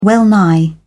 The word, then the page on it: well-nigh: British English